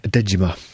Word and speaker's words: Dejima: And can you help me pronounce the island name It's called Dejima. – Dejima.